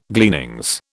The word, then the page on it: gleanings: British English